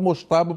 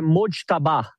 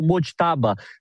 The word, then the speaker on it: Mojtaba: E falam que a pronúncia é Mojtaba ou Mojtaba, sempre com esse som de um DJ mais ou menos no meio do nome.